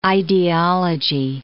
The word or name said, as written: ideology